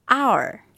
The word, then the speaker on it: hour: งูเหลือมข้อสังเกตมีคำบางคำที่ขึ้นต้นด้วยพยัญชนะ h แต่เวลาออกเสียงจะไม่ออกเสียงตัว h จะออกเสียงสระเช่น hour